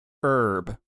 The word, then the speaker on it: herb: See, job's the one that's throwing me off, because herb has the silent H.